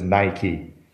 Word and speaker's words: Nike: Vielleicht liegt es ein bisschen an Nike, aber die richtige Aussprache für die Firma ist Nike.